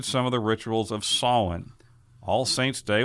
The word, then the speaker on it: Samhain: but that's not the way you really say it. It's pronounced Samhain.